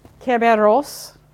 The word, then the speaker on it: Kerberos: Greek, Kerberos, emphasis on the first syllable.